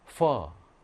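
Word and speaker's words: phở: PH는 영어의 F 발음이에요. F 발음. 자, FIM. 자, 이거 우리가 아는 거야. 여러분들이 좋아하는 음식이야. 뭘까요? 퍼. 쌀국수, 우리 퍼지, 퍼. 그거를 퍼라고 해.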